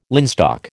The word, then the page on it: linstock: American English